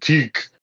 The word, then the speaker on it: Tetch: Oh, his name is pronounced Teek. – All right, Teach.